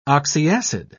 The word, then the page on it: oxyacid: British English